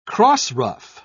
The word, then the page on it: crossruff: British English